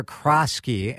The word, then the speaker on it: Crasqui: Crasqui.